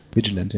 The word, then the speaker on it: Vigilant: Dann kommen wir zu den Serpentes und da haben wir die, äh, wie spricht man das jetzt aus? Vigilant? – Vigilant, ja.